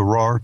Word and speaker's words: Rahr: So that's the RAR Two Row? – Yeah, RAR Two Row, grown in near a place in Canada that we all like. – Which is spelled RAHR, correct? – Yes.